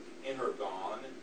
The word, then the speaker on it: energon: Energon, E-N-E-R-G, long O-N. And we see that, and it sounds like what? Energy, right?